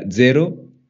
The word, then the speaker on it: zero: Well, I'll start with zero because I'm presuming zero is the same.